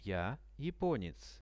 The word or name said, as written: ja japonec